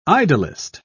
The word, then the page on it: idyllist: British English